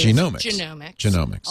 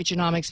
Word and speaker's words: genomics: The correct way to say it is like the human genome. It is genomics. – A lot of people say genomics because it's more like economics. – Like economics, but genomics.